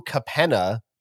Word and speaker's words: Capenna: First off, there has been a delay of the new Magic the Gathering set, Streets of New Capenna, which I hope I'm saying that right.